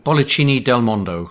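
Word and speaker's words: Bollicine del Mondo: I suppose the reason I heard about you guys is because you won the Bonaccino del Mondo Award. Is that how you pronounce it? – Bollicini del Mondo, which Bollicini is sparkling of the world, I think it means in Italian.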